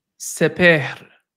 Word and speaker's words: Sepehr: It's spelled SEPEHR. Sepehr. Sepehr. Oh, there you go, man. You got that R going.